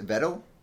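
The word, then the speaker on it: Yveltal: Which he explains is, how you pronounce this, Ivetel? – Ivetel. – Ivetel. – There's an L. – Ivetel. – Right. – Ivetel. – Yes.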